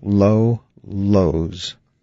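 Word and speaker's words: Lo Loz: it's a product called Low Lows. And to spell it, it's L-O space L-O-Z.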